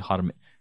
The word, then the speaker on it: Hermès: I know I'm saying the name incorrectly because it's a French name, but it's actually supposed to be Hermès.